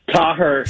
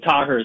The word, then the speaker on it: Taher: Taher. Everyone says Taher. It's the American way, but it's Taher is how we pronounce